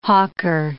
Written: hawker

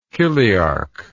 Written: chiliarch

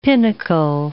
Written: pinnacle

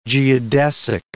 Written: geodesic

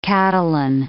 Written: Catalan

